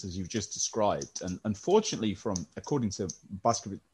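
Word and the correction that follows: Bošković: excuse me, can you remind me of my pronunciation? – Boskovich.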